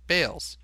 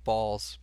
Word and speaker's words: Baals: His last name, Baals, which all his descendants have always gone by the last name pronunciation Bales. Baals. – Yeah. – Harry Bales is how his parents intended him to go by. He said, screw it. Go ahead and... I'm going to pronounce my last name Balls. Bawlz. Even though it's Baals.